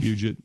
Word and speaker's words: Puget: Dude, they're on the Puget Sound or whatever it's called. – Puget, yeah.